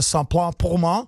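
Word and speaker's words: Ça plane pour moi: tak dokážu tenhle název vyslovit ve francouzštině tak, jak se správně vyslovit má, tedy skladba od Plastika Bertranda, jmenuje se Saint-Plain pour moi.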